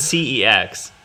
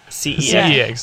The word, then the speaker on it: CeX: Is that how you say it? – It's CEX. CEX.